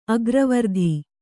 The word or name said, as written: ಅಗ್ರವರ್ತಿ